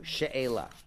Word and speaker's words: she'ela: How do you say it in Hebrew? She'ela.